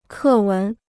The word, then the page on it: 课文: kè wén